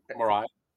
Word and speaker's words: Mirai: His breast cancer tool, Mirai, did I pronounce that correctly? – It's a valid way to pronounce it. I used to say Mirai.